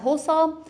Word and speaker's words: حسام: این نکته بگم، حسام تلفظ عربیش با ضمه است، یعنی حسامه به معنای شمشیر، ولی خب رفته رفته تو زبان ما شده حسامی.